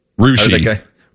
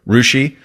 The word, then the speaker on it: Russia: How did you say Russia? – Rushi. – Rushi. – And Baker confirmed that, and he would know.